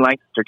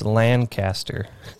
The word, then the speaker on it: Lancaster: See, no, you said it wrong. It's Lancaster. If you're going to be in Tennessee, yeah. Now that you're in Tennessee, yeah, you have to start saying that's Lancaster County.